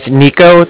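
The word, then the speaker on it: Niko: My name is Nico. That's N-I-K-O. I'm calling with the Bank of Cardiff, and that's spelled C-A-R-D-I-F-F, as in Frank.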